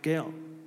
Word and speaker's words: gaal: And I'm not going into it too much, but the Hebrew word there, if I'm pronouncing it right, is Gael. G-A-A-L, Gael.